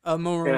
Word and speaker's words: Moroni: I think it's called was it the Angel of Moroni? How you would pronounce that? – Moroni.